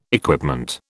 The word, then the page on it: equipment: British English